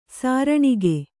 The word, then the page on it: ಸೀರಣಿಗೆ: sīraṇige